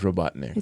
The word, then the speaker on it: Robotnik: One of my first books I ever read, I pronounced it wrong as a kid. – What'd you say? – Robothink. – You know, that happens. – But it's Robotnik.